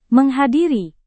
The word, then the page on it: menghadiri: Indonesian